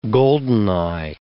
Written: goldeneye

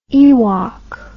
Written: ewok

